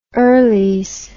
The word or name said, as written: earlies